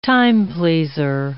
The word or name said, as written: timepleaser